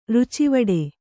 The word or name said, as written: ರುಚಿವಡೆ